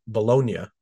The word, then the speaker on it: Bologna: He played in the Italian league for Arimo Bolo- uh, Bologna, uh, Bologna, excuse me.